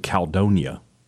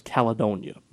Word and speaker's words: Caledonia: So that you don't you stress that E. You put emphasis on the E. That's correct. – Okay. I put the emphasis, it's Caledonia. It's the E is silent in mine.